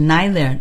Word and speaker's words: neither: And、perhaps British or some very educated Americans will use neither, the more formal pronunciation. – Thank you. 我们听到 读这个时候读的是 neither, nor.